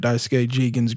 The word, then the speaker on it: Daisuke Jigen: So we decided to watch the Lupin the Third, Daisuke Jigen's Gravestone trailer. I think I'm pronouncing all of that correctly. – Oh, yeah, you did.